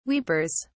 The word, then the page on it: weepers: British English